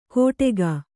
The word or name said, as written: ಕೋಟೆಗಾ